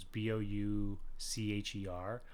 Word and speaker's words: Boucher: Yeah, Jace Jace Butcher is I kept pronouncing it Butcher because it's B O U C H E R, but we looked it up recently and it's Boucher because it's like a French pronunciation.